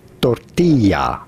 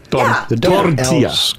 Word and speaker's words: tortilla: In Spain, you would say tortilla. – Tortilla. – Come down as a Y.